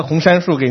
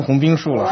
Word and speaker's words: 红杉树: 这个上星期不好意思哈，上星期这个显出了我这个文化水平很低哈，把一棵红杉树给念成红冰树了，这个下来好几个弟兄跟我提醒哈。这个回去查了一下，果然是红杉树。